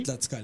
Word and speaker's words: Tlaxcala: Después eh, Tlaxcala. Tlaxcala. – ¿Lo pronuncié bien? Sí. Tlaxcala. Tlaxcala.